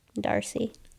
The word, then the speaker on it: D'Arcy: Okay, so yeah, so Emma, as long as I'm saying it right, is D'Arcy? – D'Arcy. – It's a D with a little comma.